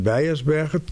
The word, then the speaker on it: Beijersbergen: The Dutch do not pronounce the letter N, on the end of a word. – Oh, we don't think it's necessary, because we want to have Beijersberg.